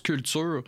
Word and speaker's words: QCLTUR: Ben, ça se prononce Culture. C'est Q-C-L-T-U-R, dans le fond. C'est comme ça qu'ils l'écrivent, mais ça se prononce Culture pour Québec Culture.